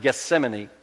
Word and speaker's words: Gethsemane: Now, we pronounce it Gethsemane.